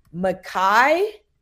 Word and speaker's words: Makai: The first I said Micah, and Brian was like, no, that is not his name.